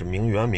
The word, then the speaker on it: 名媛: 自己的能力和自己所驾驭的财富不匹配。有些呢是真拍，因为家里真有钱，有些是租去，所谓的名媛，啊，社会名媛，是名媛名媛呀，啊，反正就他就按名媛来发音吧，啊，说咱十个人啊，团一个五星级酒店的早餐券，啊，那十个人合伙啊，租一牢，然后你拍一小时，我拍一小时，拍完给人送回去。